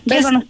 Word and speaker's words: Veilbound: Así se pronuncia, Veilbound Studios. – Sí, que Veil es B corta E... I L y Bound es B-O-U-N-D guión bajo estudios,